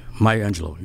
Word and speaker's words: Maya Angelou: And so Maya Angelou? Am I saying that right? – Maya Angelou.